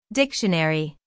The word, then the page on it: dictionary: American English; female